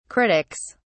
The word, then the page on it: critics: British English